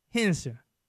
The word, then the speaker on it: Henderson: The folks in Henderson call it Henson. Two syllables, WD. Get that down. Henson, North Carolina.